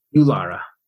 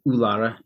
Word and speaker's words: Eulara: Eulara. – Eulara? – I guess you could pronounce it Eulara. U-L-A-R-A. Eulara. – Eulara. Okay.